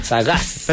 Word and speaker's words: Sagaz: Sagaz. – Pero es con ese al final. – Con ese, con acento en la última.